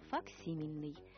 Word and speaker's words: факсимильный: Ударение именно такое «факсимильный».